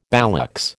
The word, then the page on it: ballocks: American English